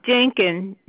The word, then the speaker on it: Denkin: Rebecca, R-E-B-E-C-C-A. – And the last name? – Dinkin, D like David, E-N-K-I-N. – D-N-K-I-N? – D-E-N-K-I-N.